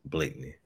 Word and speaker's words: Blakeney: You said it right the first time, though. It's Blakeney. – Is it Blakeney? Why is it say? Well, it's got some extra letters.